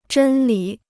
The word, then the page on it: 真理: zhēn lǐ